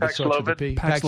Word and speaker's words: Paxlovid: And now with these rebounds off Paxlovid, did I say that right? I always seem to say it wrong. I just pick a different drug. – Paxlovid.